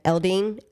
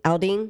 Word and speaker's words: Aldean: I might be pronouncing his last name wrong. I'm not a country guy, you know, but the famous country singer, Jason, is it Eldean? – How do you spell it? – A-L-D-E-A-N. Eldean? Aldean? – Aldean, I think.